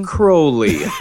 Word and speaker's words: Crowley: Crowley is how you pronounce it.